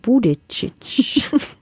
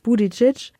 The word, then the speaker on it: Buttigieg: Mit dem Gewinner Buttigieg. – Wie? – Buttigieg. – Sag's nochmal. – Buttigieg.